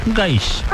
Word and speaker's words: Gaich: Gaich. Ah, así se pronuncia. – Gaich. – Ah, ¿con qué así? – ¿Cuándo decir? Gaich. Okay. O Fer Gay, o Fer Gay, como ustedes. La verdad es que como ustedes quieran y prefieren, como se sientan más cómodos, somos el amigos.